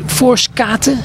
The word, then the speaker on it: Voorschoten: TC Voorskaten, zeg ik dat goed? Ja.